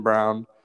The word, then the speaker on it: Braun: Again, another thing that we did in the last podcast is we pronounced this guy's name wrong. Christian Brown. We were calling him Christian Braun. His name's Christian Brown. It's weird spelling, but he had 6 points, 8 rebounds.